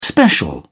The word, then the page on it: special: British English